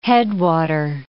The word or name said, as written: headwater